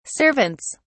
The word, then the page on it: servants: British English